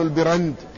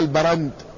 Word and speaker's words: البرند: ولهما، أي للشيخين البخاري ومسلم، محمد بن عرعرة بن البرند بكسر الباء وبفتح الراء، هكذا ينطق، هكذا نطقه الأمير يعني ابن ماكولة، وبعض أهل العلم سماه البرند بفتح الباء.